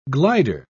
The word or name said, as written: glider